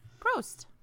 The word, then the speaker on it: Prost: Prost. – Prost. Mm. I think that's the word. How do you spell that? – P-R-O-S-T. – Prost.